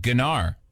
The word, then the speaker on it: gnar: You're not supposed to pronounce the G? Okay, that's why everyone looked at me when I said gnar.